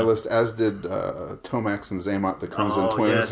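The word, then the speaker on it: Xamot: Oh, it's Zaymot.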